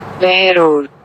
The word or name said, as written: Behror